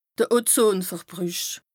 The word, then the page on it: Ozonverbrüch: Bas Rhin